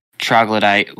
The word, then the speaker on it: Troglodyte: But anyways, you get towards third track, Troglodyte, which I hate. No, I can never say that right, but you know. – You said it perfectly.